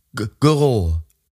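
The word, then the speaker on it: Guérot: Und er war gemeinsam mit Ulrike Guerreau. Habe ich das richtig ausgesprochen? – Güro. – Güro? Güro? – Ja, Güro. – Güro. Ulrike Güro, ja.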